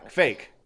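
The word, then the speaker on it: kveik: Yeah, fake, fake, fake. That's apparently it's how, fake. fake, yeah. That's apparently as close as we our our Anglicanized mouths can get to pronouncing this word.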